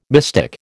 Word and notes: mystic: American English

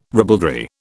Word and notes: ribaldry: British English

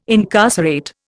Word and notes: incarcerate: Indian

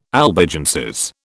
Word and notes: Albigenses: British English

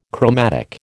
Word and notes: chromatic: American English

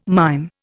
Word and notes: mime: Indian

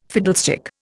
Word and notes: fiddlestick: Indian

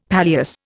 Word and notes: palliasse: Indian

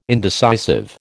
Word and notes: indecisive: American English

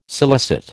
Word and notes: scilicet: American English

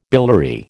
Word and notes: biliary: American English

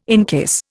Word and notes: encase: Indian

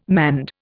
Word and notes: manned: Indian

